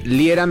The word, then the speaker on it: Liera: Mis redes sociales, Fernando Liera, me encantará platicar contigo. Mi apellido se escribe L-I-E-R-A, así me puedes encontrar en Instagram, en Facebook, en todas las plataformas.